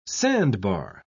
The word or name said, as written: sandbar